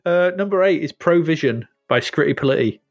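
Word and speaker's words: Scritti Politti: You have to say it like that. Skritti. Skritti Politti. You can't say it any other way. – Yes, because it can't be Skritti Politti, can it? – If you were doing this in like Give Us a Clue, rhymes with fighty. Politti? – Bitey. – Rhymes with bitey.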